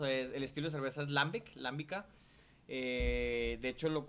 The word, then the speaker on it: Lambic: Eh, se llama Lambic, así como se escribe la, cerveza L-A-M-B-I-C.